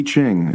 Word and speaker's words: I Ching: The I Ching. It's not called the I Ching, it's the I Ching. – Well, it's the letter I, but I think it's pronounced E.